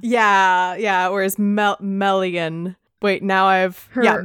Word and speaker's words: Melian: Chapter four is of Thingol and Melian. Are those correct pronunciations? – Correct. You even did Melian right. I've been mispronouncing Melian's name for years. I keep wanting to say Melian, and that's incorrect. It's Melian.